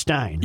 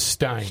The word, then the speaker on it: Stein: I'm trying to remember what Bill Stein said it's pronounced Stein, right? – E-I-N is Stein.